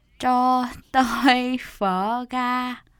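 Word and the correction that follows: gà: 버가. – 네. – 버가. 가 하면은 지하철역이 돼버려가지고. – 아, 그러네요. – 네, 버가. 발음이 좀 중요합니다.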